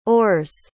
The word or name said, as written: or's